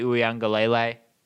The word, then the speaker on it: Uiagalelei: But let their backup quarterback, DJ Uiagalelei, kind of slash through that, nice.